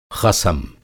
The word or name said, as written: ख़सम